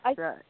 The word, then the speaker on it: Hafsa: You've got to get it, Hafsa. I do apologize if I have not pronounced it right. – No, you say it as it's written, that's it.